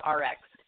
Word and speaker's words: ARIIX: And it's pronounced the same. It's called Rx. So exactly. But it's A-R-I-I-X or I-X-X? – A-R-I-I-X.